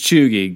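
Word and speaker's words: cheugy: Pronounced chuggy, got its own write-up in the New York Times.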